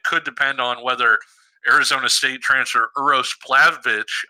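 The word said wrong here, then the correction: Plavšić: uh, I'm Plavsic, excuse me, I'm sure I butchered that.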